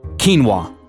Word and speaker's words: quinoa: Quinoa. – Oh. – Anytime I see the word quinoa, my brain automatically goes, oh, quinoa. – That's how it's spelled, yeah. – Yeah, because it's Q-U-I-N-O-A.